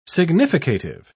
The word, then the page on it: significative: British English